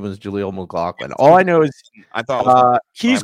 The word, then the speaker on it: McLaughlin: McGloin looked really good the other day. You know? McGloin, I think is that how you pronounce his name? – McLaughlin, Jaleel McLaughlin.